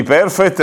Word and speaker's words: perfect: Com'era quello di perfect? Perfect. Perfect quando l'accento è sull'ultima, cosa significa? Ah, perfezionare. Ok. Mentre perfect è perfetto.